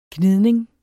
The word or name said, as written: gnidning